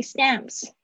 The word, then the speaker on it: stamps: 那这边呢，就是我今天早上改作业的时候比较发现，就是大家那个 a 的声音好像会比较没有办法向下压 a 啊，像 apple 那个声音。 Stamps，stamps，不要念成 stamps，stamps 不是 stamps，stamps 啊，稍微就是你现在在练习的时候嘴型稍微夸张一点。那你在放松的时候就比较会念出正确的发音。好，Excuse me, where can I buy stamps？